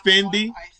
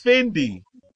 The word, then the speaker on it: Fendi: Today's Italian designer of the day is Fendi. – I said it right. I know I went tripping. It's Fendi. See? We sound crazy. It was Fendi.